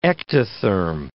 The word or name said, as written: ectotherm